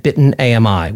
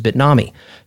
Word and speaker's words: Bitnami: Most recently, you have Wavefront, Heptio, or Heptio, however they want to pronounce it, Cloud Health, and Bitten AMI, which some people mispronounce as Bitnami.